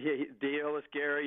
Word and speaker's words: Deolis Guerra: Am I even pronouncing that right? – Yeah, he's kind of Deolis Guerra. Yeah, it's funny because I've heard different broadcasters and different people with the Phillies even pronounce his name a little bit differently.